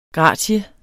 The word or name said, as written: GRATIEN